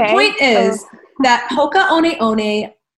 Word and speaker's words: Hoka One One: It's spelled Hoka, H-O-K-A, and then it looks like 1-1, and I always thought it was pronounced Hoka 1-1, but it's actually Hoka One One.